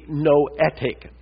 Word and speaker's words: noetic: Theologians call this the noetic effect of the fall. That's spelled N-O-E-T-I-C. It's not Noah, but noetic, the Greek word nous for mind.